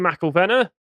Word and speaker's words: McIlvenna: I'm your host, joined today by Peter McIlvenna. I pronounced that right? – You did, spot on.